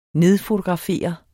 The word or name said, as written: nedfotografere